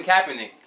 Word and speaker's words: Kaepernick: The Kaepernick. The Kaepernick. Thank you. – Okay, because I was Kaepernick. whoever. – It, it's definitely Kaepernick.